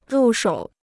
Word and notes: 入手: rù shǒu

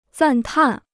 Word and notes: 赞叹: zàn tàn

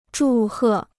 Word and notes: 祝贺: zhù hè

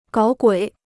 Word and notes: 搞鬼: gǎo guǐ

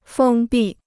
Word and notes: 封闭: fēng bì

